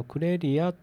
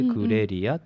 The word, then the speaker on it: CREREA: ことでしたね、うん、なるほどね、うん、実はね、はい、クレリアっていうこのラジオ番組もそういう名前です、はい、でクレリアはこのラジオだけじゃなくていろんな大学生の方がいろんなことを挑戦する場を提供しています、うん、でこのクレリアっていうのはね、 CREREA というふうな綴りでクレリアって読みます、はい、うんうん、で実はこれはキャリアっていうふうな言葉がありますよね、キャリア形成のキャリア、はい、うんうん、これのまあ、綴りをね字をね入れ替えたんです。